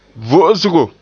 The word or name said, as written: vo'osgo